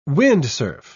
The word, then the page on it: windsurf: British English